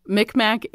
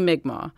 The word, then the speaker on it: Mi'kmaq: Mi'kmaq is an anglicized pronunciation of her mother's nation. Now we say Mi'kmaq, but back in the day, Mi'kmaq was used pretty regularly.